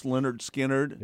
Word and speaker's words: Lynyrd Skynyrd: And I had Lynyrd Skynyrd, pronounced Lynyrd Skynyrd.